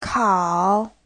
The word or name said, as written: kao3